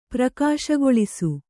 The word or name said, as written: ಪ್ರಕಾಶಗೊಳಿಸು